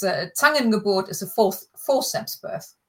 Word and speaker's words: Zangengeburt: Es war eine Zangengeburt. Zangen. Oh, can you say it properly? – Es war eine Zangengeburt.